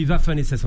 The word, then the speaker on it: Waffen-SS: Oui, en revanche, le parti frontiste, le Front National, on dit Waffen-SS en allemand, c'est le W se prononce V, comme Volkswagen.